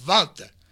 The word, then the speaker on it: Walter: It is Walter. That's how you say it in his native dialect. – Yeah, in Austrian.